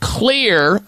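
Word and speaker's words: Xlear: And that is to use something called Clear, spelled with an X, X-L-E-A-R, Clear nasal sprays.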